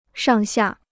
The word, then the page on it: 上下: shàng xià